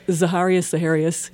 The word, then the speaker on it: Zaharias: Zaharias, yeah.